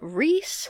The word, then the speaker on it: Rhys: But in the name Rhys, which is spelled R-H-Y-S, it has an E sound.